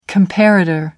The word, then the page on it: comparator: American English